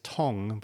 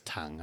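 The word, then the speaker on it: Tongue: I mean, the commentators were pronouncing his name as Tong, but I prefer it as Tung. I mean, yes, yeah. – Yeah. – Yeah, he's, that's how it's spelled. I mean, spelled, if it's Tong, it'd be T-O-N-G, right? – Exactly, yeah. Mm. So the U-E are there so that we know how, like, teaches people how to spell Tung.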